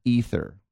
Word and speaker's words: Aether: Chaos and Aether, pronounced with a silent A, A-E-T-H-E-R.